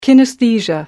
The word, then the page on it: kinesthesia: American English